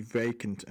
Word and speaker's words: Vacant: they really wanted to say with a specific emphasis on the second syllable in Vacant, because that way they could get away with saying it on top of the Pops.